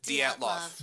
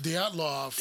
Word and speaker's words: Dyatlov: Yes, I mean, there's an, the area is called the uh, Daylodoff. Did I say that right? Dayloff? Dyatlov. – Dyatlov.